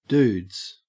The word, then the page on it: dudes: Australian English